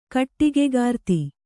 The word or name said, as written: ಕಟ್ಟಿಗೆಕಾರ್ತಿ